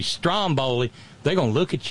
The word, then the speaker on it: Stromboli: The stress is on the first syllable. It's a volcano on an eponymous island off the coast of Italy. Stromboli. Like Strom Thurmond, right? Well, you know, we're accustomed to with Latin languages to putting the emphasis on the next to last syllable.